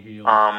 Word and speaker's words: Hield: I've never known how to pronounce the kid's last name correctly, but Buddy Hyde, is that how it's pronounced? Healed? I don't know. Basketball player guy. – Buddy Hield.